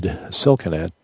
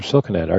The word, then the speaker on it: Silkenat: Is the T sounded or is it silent? – Yeah, it sounded Silkenat, yep.